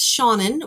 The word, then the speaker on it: Shannon: My name is Shannon,